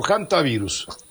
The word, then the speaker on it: hantavirus: ¿Hantavirus o hantavirus? – Se dice hantavirus, aunque se escribe con H, por la cuestión anglosajona que hacen que la H suene como J. Pero es hantavirus.